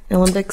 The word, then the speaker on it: alembic: Is distilled in the alembic. Am I saying that right? Alembic still? – Yeah, an alembic still is a style of, you know, kind of old world still.